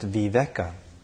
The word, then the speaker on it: viveka: viveka.